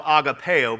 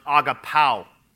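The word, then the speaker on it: agapao (ἀγαπάω): The word Jesus used for love is agapao. Sometimes I call it agapeo, but it's actually pronounced agapao.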